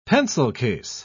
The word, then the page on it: pencil-case: British English